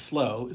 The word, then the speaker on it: Slough: The Slough House, spelled S L O U G H, but cleverly pronounced as Slow,